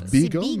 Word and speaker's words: bagel: C'est ça se prononce beagle, mais oui, OK.